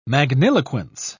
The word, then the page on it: magniloquence: British English